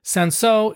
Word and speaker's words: Cinsault: Now, Sansot is spelled not that way. It's spelled C-I-N-S-A-U-L-T, but it's pronounced Sansot.